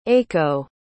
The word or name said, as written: aico